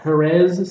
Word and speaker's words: Jerez: But yeah, the Macallan Highland Single Malt Scotch Whiskey, 18 years old, exclusively matured in selected sherry oak casks from, how do you say that, Jerez, Spain? – Yeah, I did it.